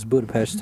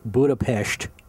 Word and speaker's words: Budapest: And Jarvis, 00 Jarvis, mentions Budapest tonight. – Right. – Or correctly pronounced Budapest.